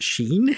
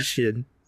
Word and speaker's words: Shin: But then the Japanese guys come on, and they're saying Shin.